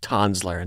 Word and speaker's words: Tanzler: Karl Tanzler, and we learned it is pronounced Tanzler, not Tanzler.